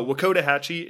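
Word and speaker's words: Wakodahatchee: So Wacotahatchee is the proper pronunciation of the wetlands that we went to while we were down in near Orlando area.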